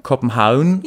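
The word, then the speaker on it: København: Übrigens sieht man das noch, wenn man zum Beispiel den Namen Kopenhagen, das wird ja Kopenhauen ausgesprochen und wird aber mit V geschrieben.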